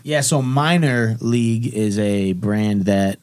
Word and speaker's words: Miner: But Minor, it's spelled M-I-N-E-R, like a coal miner, you know, in those ways.